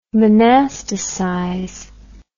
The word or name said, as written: monasticize